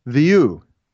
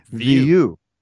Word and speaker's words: Vu: Uh, his first name is Viu. Viu. Viu. Viu. – But mm-hmm. it's not pronounced Vu. It's pronounced Viu. Viu.